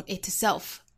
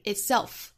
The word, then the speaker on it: itself: 首先呢，我们先发好 beautiful, 尾部是有一个舌侧音的，那在跟后面元音连读呢，会带出一个 l 的音， beautiful in, 那 in 后面的这个鼻音，在跟后面元音又形成辅元连读， in itself, 这里还要注意 itself, 这里面这个 t 的发音是憋住的，是不完全爆破的，不要发成 itself, 应该是 itself, 那再跟前面连就是 in itself, 那这三个词我们放在一起会有一点拗口，语速先降下来，熟悉了之后再加速。